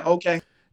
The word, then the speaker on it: Okeh: Oh, yeah, Okeh.